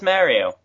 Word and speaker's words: Mario: And the voiceover guy, he says Mario. – Very clearly. – Case closed.